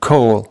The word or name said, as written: coal